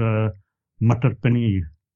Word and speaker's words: matar paneer: And then I had an Indian dish with it called matar paneer.